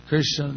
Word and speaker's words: Krishna: So Krishna means literally is two syllables, Krish and Na.